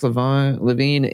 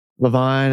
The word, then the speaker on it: LaVine: Learn to say his name. – LaVine, I know, LaVine. – You say LaVine every time. – LaVine! – You have to correct yourself every single time.